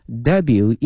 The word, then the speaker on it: debut: 大使这个词哈，希望同学们记下了 ambassador。ambassador ambassador 哈大使 at the end of 2017。而那么呢今年的2月1日呢，他的获奖作品呢在纽约首次亮相。On February 1st, her award-winning creations 获奖的英文叫 award 这个词 ,A-W-A-R-D, 奖项 ,winning, 获奖哈 ,award-winning creation made its debut in New York. 首次亮相哈，英文有一个专门词叫 debut, D-E-B-U-T,但同学们注意了，这个后面这个 T 呢是不发音的，是 make something 或 someone's debut，